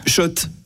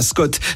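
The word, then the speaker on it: Schott: Oui, Shot. Et pas Scott, ça fait des années qu'on prononce mal la marque.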